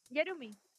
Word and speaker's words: Jarumy: Ay, qué bueno, qué bueno, estoy pronunciando bien tu nombre. ¿Yarumi o Harumi? – Yarumi.